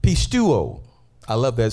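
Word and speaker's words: pistuo (πιστεύω): So this Greek word is pistuo. That's how you pronounce that, pistuo.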